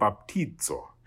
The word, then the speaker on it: baptizo: And the Z, or as the rest of the world, other than the United States, call a Z, is pronounced like a DZ, as in ads. So we get baptizo.